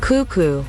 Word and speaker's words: cuckoo: En inglés también, solo que allí la sílaba acentuada es la primera y por eso dicen cuckoo.